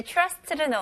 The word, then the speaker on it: trust: 여기서 이제 TR로 시작하는데 느낌은 살짝 CH 발음으로 한번 해보세요. Trust. 오케이? Trust보다는 CH 발음. Trust.